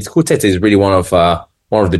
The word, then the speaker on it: Coutet: it's been in the same family for Chateau Coutet. So you pronounce the T, so C-O-U-T-E-T.